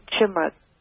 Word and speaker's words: chimera: And that is what scientists and ancient mythology have always called chimera. C-H-I-M-E-R-A. – Yeah. It's pronounced the number chimera. – Chimera, that's it.